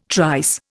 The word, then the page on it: trice: Indian